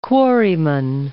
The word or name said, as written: quarryman